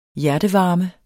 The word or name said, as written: hjertevarme